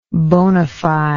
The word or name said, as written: bonafide